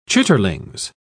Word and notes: chitterlings: British English

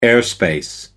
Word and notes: airspace: American English

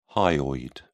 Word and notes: hyoid: British English

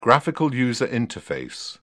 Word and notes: graphical user interface: British English

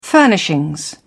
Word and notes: furnishings: British English

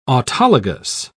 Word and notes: autologous: British English

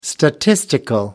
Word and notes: statistics: American English

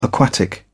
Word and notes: aquatic: British English

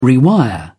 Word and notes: rewire: British English

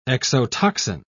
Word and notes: exotoxin: British English

